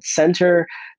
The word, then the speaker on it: Centr: All right, the second and last app that I will promote here on this channel is going to be one called Center by Chris Hemsworth, aka Thor. It is spelled C-E-N-T-R.